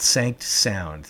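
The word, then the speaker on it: SanctSound: Navy and stemming out of some litigation of ours half a dozen years ago, called Sanct Sound. The first syllable is just short for sanctuaries and national marine sanctuaries.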